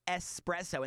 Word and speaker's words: espresso: Well, I think it's no surprise to know that people are always mispronouncing espresso and saying expresso.